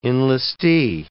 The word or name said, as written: enlistee